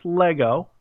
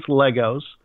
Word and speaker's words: Lego: And it's pronounced Lego. There's no such thing as Legos, just like there's no such thing as deers. There's deer. Okay? – Okay.